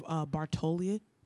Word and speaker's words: Bartoli: We have Bartoli. I can never pronounce any of these names. She won. – That's her name. You said it right.